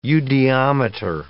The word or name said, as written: eudiometer